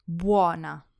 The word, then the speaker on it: buona: And to pronounce it well, remember that the U is like a W. Bu. Bu. Buona.